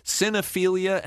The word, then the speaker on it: Cinephilia: Cinephilia. Boy, I added a syllable like Homer there. – Yeah. – Homer Simpson. Saxophone. Cinephilia and Beyond.